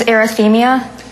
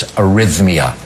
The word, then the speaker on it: arrhythmia: What is arrhythmia? – No, sorry. You mispronounced it in such a way as to make it unacceptable. It's arrhythmia, arrhythmia. Not arrhythmia. You added a syllable.